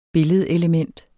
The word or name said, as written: billedelement